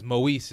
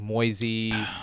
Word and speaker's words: Moise: Well, that's why I shortened my name to Mo, and just because Moise is the full name, but people can't pronounce Moise the first time. It's like Moisey. Moise, Idaho. Moise, Moise, Idaho.